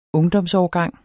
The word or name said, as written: ungdomsårgang